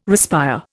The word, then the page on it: respire: Indian